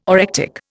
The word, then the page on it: orectic: Indian